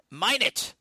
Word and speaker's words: Minot: Is it Minot? – John in Minot, North Dakota.